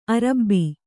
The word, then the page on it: ಅರಬ್ಬಿ: Kannada